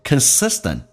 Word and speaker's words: consistent: You focus on 这句话就变成 I'm here to tell you that anything you focus on a consistent basis， on 不被强调，有一个连音叫 on，所以它速度很快，变成 on a consistent basis Consistent 指的是持续的，美式发音那个 T 要发成 D 叫 consistent